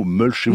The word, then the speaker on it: mulcher: Alors vous avez parfaitement employé le mot mulcher. Vous l'avez dit. – M-U-L-C-H-E-R. – Vous l'avez prononcé comme on doit le faire à l'anglo-saxonne, compliment.